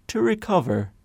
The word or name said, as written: to recover